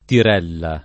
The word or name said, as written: tirella